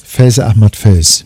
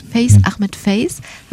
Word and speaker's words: Faiz Ahmed Faiz: Faiz Ahmed Faiz. – Faiz Ahmed Faiz.